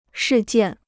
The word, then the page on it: 事件: shì jiàn